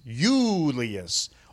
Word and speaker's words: Julius: J. It's like yogging. Yes, Julius. All those fins go with the soft J.